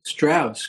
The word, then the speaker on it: Straus: And did I get your last name pronounced correctly? – Straus, correct.